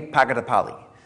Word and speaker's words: Pagadapalli: We support a missionary in India named he has a funny name, Manny, and his last name is hard to say. It's Manny Pagadapalli is how you say his name.